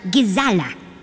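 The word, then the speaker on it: Ghazala: It's also an Arabic word pronounced gazala. – Gazala. It sounds like gazelle.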